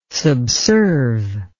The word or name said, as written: subserve